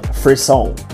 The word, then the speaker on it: frisson: In the discussion portion, we are going to be talking about Frisson, which I just learned it's pronounced that way.